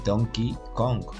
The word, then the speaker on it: Donkey Kong: Cuando estábamos hablando de la Nintendo 64 que dijo, que dijo, dijo Donkey Kong en vez de Donkey Kong, que es la pronunciación correcta en inglés.